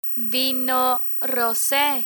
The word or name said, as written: vino rose